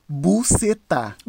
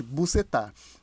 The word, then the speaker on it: Boucetta: Fia, tinha uma mulher que o sobrenome dela era Bucetá. se pronuncia assim, gente. Se pronuncia desse jeito, tá, gente? Bucetá. Era B-O-U-C-E-T-T-A.